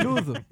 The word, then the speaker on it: Loathe: Ah c'est Lose, L-O-A-T-H-E, Lose. – Lose, je suis très très mauvaise avec les prononciations. – Et t'as pas dit que t'avais eu 20 en anglais tout à l'heure? – Je suis très très mauvaise avec les prononciations. – Oh, c'était méchant. Oui, c'est loathe. Oui, bah écoute.